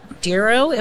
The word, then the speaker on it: Dero: Joining me today is Lou Feinberg in advocacy sales at Dero.